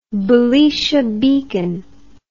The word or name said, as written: belisha beacon